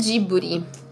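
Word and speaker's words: Jiburi (ジブリ): em ah, japonês é Jiburi.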